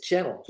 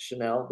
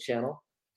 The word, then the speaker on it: Chanel: It's Channel, just like TV. – Okay. Some people are really fancy about their names sometimes. You never know nowadays. – True. Most people actually pronounce it Chanel, but it is Channel.